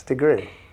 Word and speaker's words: de Gruy: It's DeGree, just like the temperature. – That's right. Okay. – Yeah, it's DeGree.